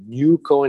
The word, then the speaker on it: NUCO: and they'll call it N-E-W-C-O at the beginning. But we heard it like N-U-C-O, so it became the name. NUCO International wanted to keep it fresh, keep it open, to keep it new. So N-U-C-O, pronounced NUCO International in one word, .com.